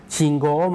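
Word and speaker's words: ชิ ง โ ก (信号): คันจิตัวที่สองออกเสียงว่าโกอุออกเสียงรวบยาวนะครับออกเสียงว่าโกโกอุเนี่ยจะออกเสียงรวบยาวนะครับออกเสียงว่าโกเพราะฉะนั้นคันจิ 2ตัวแรกนะครับเป็น 1คำนะครับออกเสียงว่าชิงโกชิงโกครับ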